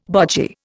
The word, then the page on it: bogy: Indian